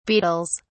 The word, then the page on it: beetles: British English